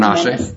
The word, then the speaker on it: Menashe: So, Dr. Menace, am I saying his name right? – Menashe. – Menashe. Oh, I like Menace better.